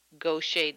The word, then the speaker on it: Gaucher: Managing Gaucher Disease. I think I said it right this time, Erica, did I? – yes, you did.